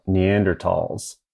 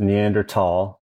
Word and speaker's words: Neanderthal: I'm going to stop right there because it feels a little unnatural to me to say Neanderthal instead of Neanderthal. But I'm committed to doing it because after several conversations and emails with the editors of the Daily, we've all made a pact to pronounce it Neanderthal, mostly because we believe that is the correct pronunciation,